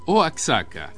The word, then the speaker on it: Oaxaca: Y agrega César en su email: otra cosa, Oaxaca. La X se pronuncia como J. Sí,